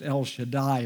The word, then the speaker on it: El Shaddai: El Shaddai, or God Almighty.